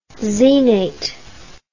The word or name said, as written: xenate